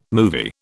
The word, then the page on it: movie: British English